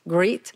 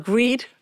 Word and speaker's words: grit: And my son says that I'm saying it wrongly, is grit, like G-R-I-T, not greed.